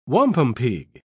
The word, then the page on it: wampumpeag: British English